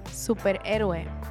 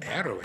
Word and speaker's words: superhéroe: Superhéroe. – Superhéroe. – Héroe.